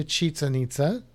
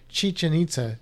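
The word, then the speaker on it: Chichén Itzá: So if you do go to Chichen Itza, I just want to see how many times I can say Chichen Itza because I said it wrong I think three times. Chichen Itza.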